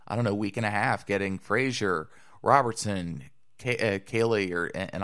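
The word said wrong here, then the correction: Kahnle: Kainley. So, I actually looked up the pronunciation this morning before we did this, right? Kainley.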